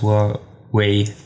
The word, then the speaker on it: Huawei: Chinesisch äh, und ja, deswegen, also wir haben uns, hat uns auch mal, meine gesagt, Huawei Way, dieses Way am Ende ja. zumindest, dass das ja. richtiger ist. Ich werde es einfach Deutsch mit Huawei aussprechen.